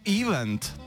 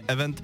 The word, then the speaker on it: event: Martin píše, dobrý den, často slyším anglické slovo event vyslovovat jako event. To je ale špatně. Správně event s důrazem na druhou slabiku.